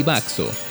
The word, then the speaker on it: baxo: La palabra que hoy usamos para referirnos al emparedado pan vaso viene de los términos pan y baxo, escrito con X, pues en el español de esa época esta sílaba tenía un sonido de S.